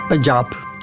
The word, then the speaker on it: Punjab: Punjab.